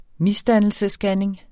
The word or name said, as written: misdannelsesscanning